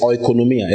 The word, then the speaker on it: oikonomia: If you have heard of the dispensation of the grace of God, that word dispensation is oikonomia. It's spelled O-I-K-O-N-O-M-I-A. O-I-K-O-N-O-M-I-A. Oikonomia. Okay? Oikonomia. And that is the Greek word from which we get the English word economy.